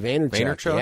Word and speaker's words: Vaynerchuk: Gary Vaynerchuk.